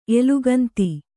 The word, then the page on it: ಏಳಾಗಂಧ: Kannada